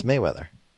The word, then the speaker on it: Mayweather: I'm I'm saying his name wrong, aren't I? – Yeah, it's Mayweather. – Yeah, I know, but I, I think I've been saying Merriweather for some reason.